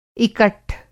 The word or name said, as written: इकट्ठ